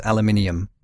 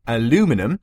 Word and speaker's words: aluminium: We'd quite like to point out that actually the British way to pronounce the element is aluminium.